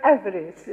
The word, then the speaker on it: avarice: Sorry, that was a funny pronunciation. Avarice. Yesterday I was asking Chang, avaricious. I just couldn't get to avarice. But avarice it is.